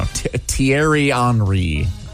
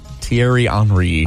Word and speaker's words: Thierry Henry: Thierry Henry. Thierry Henry. It's French.